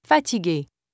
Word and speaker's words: fatigué: You have already learned the difference in pronunciation between a final E without an accent mark and one with an acute accent. – Fatigue, fatigué.